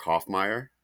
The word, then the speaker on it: Hofmeyer: I will say, as I'm looking through the episodes, I really enjoyed Mark Hoffmeyer. Is that how you pronounce it? – It is. Yeah.